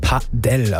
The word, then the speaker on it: Padel: Und ich sage es jetzt nochmal, die richtige Aussprache ist Padel, also Betonung auf der ersten Silbe.